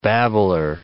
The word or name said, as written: babbler